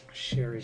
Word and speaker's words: Cherie: But with Cherry Curry, am I saying her name right? Cherry Curry? – Sherry Curry.